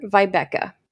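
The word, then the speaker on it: Wiebeka: It's W-I-E-B-E-K-A. So I could Vibeka. Be butchering it, but... – But I bet from Germany, it's definitely starts with a... V sound. Vibeka.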